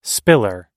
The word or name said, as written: spiller